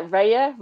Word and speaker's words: Raya: The Brentford goalkeeper, how would you pronounce his name? – Oh, I'd love to know it. – The number one goalkeeper. David Raya, yeah? – Oh, yeah. Raya. – David Raya. – Let's go with Raya.